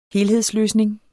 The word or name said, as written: helhedsløsning